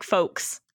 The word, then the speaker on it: folks: For me, it's like folks, like yoke.